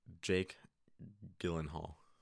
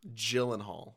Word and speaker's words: Gyllenhaal: Jake Gyllenhaal. – Gyllenhaal.